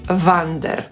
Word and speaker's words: wander: Jak to wymawiać? Czy tak samo jak Wanda? Posłuchajmy. – These are your new school books. I took the liberty of having them brought here for you. Now Tom will show you to your room. – Hedwig. – Oh, by the way, Harry, whilst you're here, it would be best if you didn't wander. – Tu usłyszeliśmy typową wymowę brytyjską.